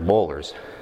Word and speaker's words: boiler: And eventually, and nobody had to tell me this, I figured it out on my own, he was talking about boilers. But they get the O-I together. They don't pronounce it boiler, they pronounce it bowl. So it's bowler.